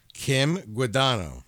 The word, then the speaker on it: Guadagno: Sure, and she also has a silent letter in her name. – Oh, she does? – She does. – Mm-hmm. All right, Kim Guadagno. – That's right.